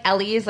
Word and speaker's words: Elise: Like Elise, like elephant.